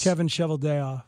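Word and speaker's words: Kevin Cheveldayoff: Kevin Cheveldea.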